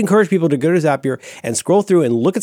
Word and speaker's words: Zapier: Zapier, it's spelled Z-A-P-I-E-R. It rhymes with happier because it will make you.